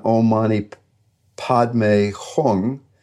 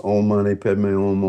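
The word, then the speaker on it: Om Mani Padme Hum: It's written Om Mani Padme Hung, but it's pronounced Om Mani Padme Om, Om Mani Padme Om.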